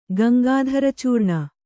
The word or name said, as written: ಗಂಗಾಧರಚೂರ್ಣ